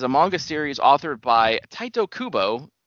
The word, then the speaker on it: Tite: It's weird because it's spelled T-I-T-E, so it's like tight or tighty, but in like the phonetic spelling, it says Taito, T-A-I-T-O. So it's like, okay, Taito Kubo.